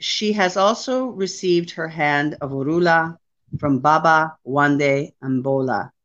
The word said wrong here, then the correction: Abimbola: Abimbola. – Abimbola.